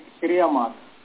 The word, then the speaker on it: श्रेयो मार्ग: जी प्रेय मार्ग जो हो गया वो जो है अविद्या है, श्रेय मार्ग जो है वो विद्या है, उसका शुद्ध उच्चारण है श्रेयो मार्ग, प्रेयो मार्ग, क्योंकि प्रेयस शब्द है श्रेयस शब्द है।